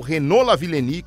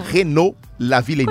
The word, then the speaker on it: Renaud Lavillenie: se a gente está falando de atletas franceses, não dá para perder a chance de citar o Renaud Lavillenie, conhecido aqui como francês do salto com vara.